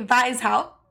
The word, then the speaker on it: Weishaupt: Okay, Weishaupt. – Yeah, there you go. That was right. – She told me that in German, that you don't pronounce the W as W. You pronounce it as a V. But his last name is spelled W-E-I-S-H-A-U-P-T.